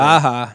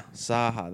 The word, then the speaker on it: Zaucha: How do you pronounce his last name? – Zaha. – Zaha, that's right. – Zaha. I would not pronounce it that way. – Right. It's spelled Z-A-U-C-H-A.